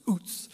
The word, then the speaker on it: Uz: There once was a man named Job from a city called Uz. By the way, I learned from Dr. Bowes, that's how you pronounce it. It's pronounced like boots, Uz.